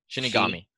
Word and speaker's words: Shinigami: Is a Shinigami, how do you say it again? – Shinigami. Yeah, exactly.